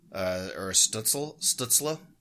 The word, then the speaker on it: Stützle: And then third line, you've got Tim Stützle. Stützle is how you're supposed to pronounce it.